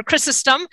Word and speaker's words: Chrysostom: We are thrilled to have today, on this day that the church gives thanks for St. John Chrysostom, or Chrysostom, as is verily pronounced.